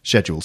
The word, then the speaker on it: schedule: In fact, pretty much every word that begins with S-C-H in English is pronounced sk, except schedule.